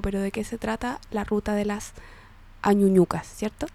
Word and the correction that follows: Añañuca: Añañuca, oye, lo digo, soy pésima pronunciando cosas que no conozco. – Con la ñ. – Con la ñ, claro.